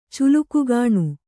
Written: ಚುಲುಕುಗಾಣು

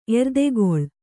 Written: ಎರ್ದೆಗೊಳ್

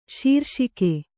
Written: ಶೀರ್ಷಕ

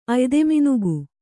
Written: ಅಯ್ದೆಮಿನುಗು

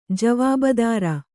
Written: ಜೀವಭದ್ರ

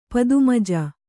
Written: ಪದ್ಮಜ

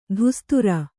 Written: ಧುಸ್ತುರ

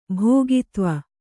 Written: ಭೋಗಿತ್ವ

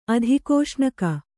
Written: ಅಧಿಕೋಷ್ಣಕ

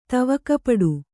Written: ತವಕಪಡು

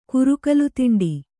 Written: ಕುರುಕಲುತಿಂಡಿ

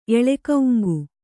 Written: ಎಳೆಕೌಂಗು

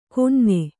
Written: ಕೊಞ್ಞೆ

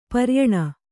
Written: ಪರಿಯಾಣ

